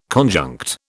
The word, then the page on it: conjunct: British English